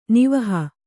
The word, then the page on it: ನಿವಹ: nivaha